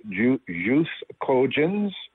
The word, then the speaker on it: jus cogens: concept called jus, jus, cogens, um, and it's spelled J-U-S space C-O-G-E-N-S, jus cogens.